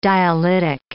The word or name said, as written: dialytic